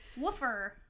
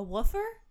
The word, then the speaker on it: WOOFER: Is it like WFR and then you just pronounce it like woofer? – No, the way they spell it is W-O-O-F-E-R.